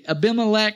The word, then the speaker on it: Abimelech: And Abimelech, that's how you say it.